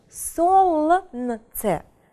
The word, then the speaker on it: солнце: Буквенно мы его пишем солнце, но произносим солнце, то есть буковка L у нас не читается.